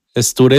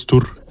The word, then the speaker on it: Ztur: Stur es una deformación de un nickname o un gamer tag. Porque yo empecé como Star. Ah, ok y era Star, pero después. Estur. – La, la pronuncia. Los ándale. No podían decir Star. – cuando me juntaba con mis amigos del Conalep, esos güeyes decían, no, es Stur.